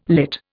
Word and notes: lit: Indian

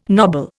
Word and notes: nobble: Indian

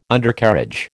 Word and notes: Undercarriage: American English

